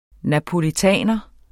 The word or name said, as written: napolitaner